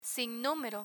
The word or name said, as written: sinnumero